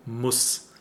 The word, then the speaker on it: muss: Because we have here Gruß and Lass or Muss. Today we would write Muss with a double S because it's a short opened U sound. Oh, Muss.